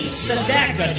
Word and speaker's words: Sedacca: no relation to Neil Sedaka, although spelled, this Sedaka is spelled S-E-D-A-C-C-A, Sedaka.